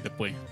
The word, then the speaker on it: Debussy: ¿Cómo se dice Debussy? – Debussy.